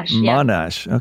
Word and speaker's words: Monash: Currently, she is studying psychology through Monash. Am I saying that correctly? Monash University in Australia? – Monash.